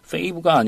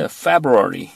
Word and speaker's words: February: Fave가 아니라 February.